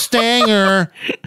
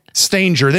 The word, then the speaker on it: Stanger: Stanger. They just say it with a soft G.